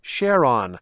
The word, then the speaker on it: Charon: which is spelled C-H-A-R-O-N. We said Charon, although space guys, like the person we heard in the story, emphasize both syllables equally as Charon. But listeners like Patrick Ivers in Laramie, Wyoming, write it's actually Charon.